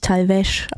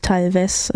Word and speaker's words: Talvez: Natürlich Talvez ausgesprochen, nicht Talvez, wie ich es eben anmoderiert habe, von AO aus dem am 13.